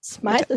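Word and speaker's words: Smyth: Smythe ist richtig ausgesprochen, ne? – Smythe ist genau. richtig ausgesprochen. Also wie Smith nur mit Y.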